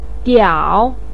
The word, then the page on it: diao3: pinyin